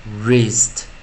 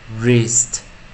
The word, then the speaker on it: wrist: 最后一个 onset 我们用 wr，那注意 wr 的时候，w 啊是 silent 不发音，所以我们只念 r，所以我们念 r r r wrist wrist wrist。